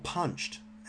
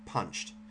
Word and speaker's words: Punchd: And the app is called Punched, and it's spelled PUNCHD, Punched. There's no E in it.